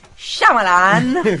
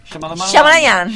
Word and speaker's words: Shyamalan: Comunque, parlando di twist, con un plot twist degno di Shyamalan che ho imparato a pronunciarlo finalmente. – Basta me, Shyamalan. – Shyamalan.